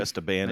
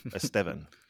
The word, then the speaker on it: Esteban: Nope, Esteban is definitely not correct. – I think that's right out. – It's where you put the emphasis on the right syllable. Esteban.